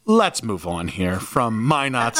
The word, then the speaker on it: Minot: Minot? – I just love that you guessed the T was silent and then tried to.